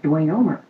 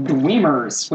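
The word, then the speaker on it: dweomer: Well, no, actually, I was reading some sort of fantasy something, and they were casting Dwemers, which I've always pronounced that as Dwemer, and I thought, no, I'm going to actually find out how to pronounce this. So Dwayomer. That's the correct pronunciation, because it's Greek, and not Latin.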